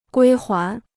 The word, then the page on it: 归还: guī huán